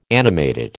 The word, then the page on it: animated: American English